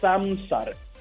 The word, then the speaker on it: Zamzar: Hay varias maneras, pero la manera más fácil se llama Samsar. Se escribe Z-A-M-Z-A-R. – Samsar.